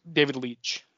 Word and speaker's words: Leitch: It's David Leitch, is that how you say it? – David Leitch. – Leitch.